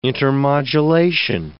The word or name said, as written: intermodulation